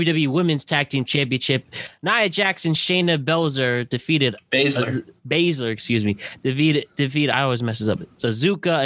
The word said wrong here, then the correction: Asuka: and Charlotte Flair. How we feel about Asuka? Okay, no more mispronunciations. Asuka and Shayna Baszler and Nia Jax and Charlotte Flair with we have brand new Women's Tag Team Champions.